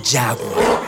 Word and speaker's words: jaguar: Sure, for example, the pink dolphin, delfín rosado, or the jaguar, jaguar, que se escribe igual que en español, pero se pronuncia distinto.